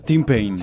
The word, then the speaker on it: Tim Payne: El jugador de la selección, escuchen, de Nueva Zelanda, Tim Payne. El nombre Tim, apellido Payne. ¿Está bien pronunciado? – Tim Payne.